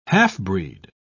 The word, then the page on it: half-breed: British English